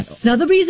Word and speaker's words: Sotheby's: Oh, Sotheby's is doing this? Oh, my gosh. – Is that how you say it? Sorry. – Yes.